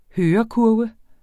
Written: hørekurve

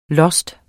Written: lost

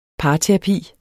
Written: parterapi

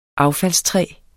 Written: affaldstræ

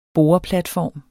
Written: boreplatform

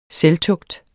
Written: SELVTUGTS

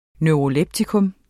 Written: neuroleptikum

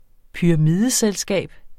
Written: pyramideselskab

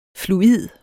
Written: fluid

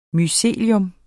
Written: mycelium

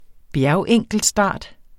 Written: bjergenkeltstart

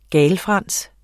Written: GALFRANSENS

